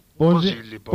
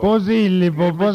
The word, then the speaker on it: Posillipo: È accaduto ieri a Posillipo. O Posillipo? – Posillipo. – Posillipo. Ci mette a fare l'accento.